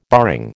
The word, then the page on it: barring: American English